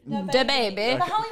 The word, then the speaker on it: DaBaby: No, I feel like I said like DaBaby. – DaBaby.